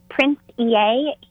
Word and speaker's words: Prince EA: How do we pronounce his name? – It's just Prince EA.